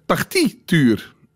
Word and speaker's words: partituur: Mies Vergalen kwam met partituur. Partituur waarbij ik dus de klemtoon op aan de voorkant moet hm. – leggen.